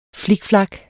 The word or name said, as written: flikflak